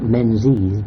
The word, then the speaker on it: Menzies: Menzies, M-E-N-Z-I-E-S, spelt Menzies, but pronounced Menzies, Scottish name,